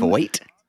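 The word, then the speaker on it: Feucht: So I've been told the correct pronunciation is John Voight, that his first name is pronounced John Voight. – And his last name is pronounced Voight.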